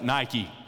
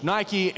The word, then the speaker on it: Nike: We pronounce that Nike. Incidentally, if you're wondering what that swoosh signifies on your shoes, Nike is pronounced in English Nike, and it means victory.